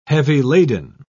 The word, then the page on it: heavy-laden: British English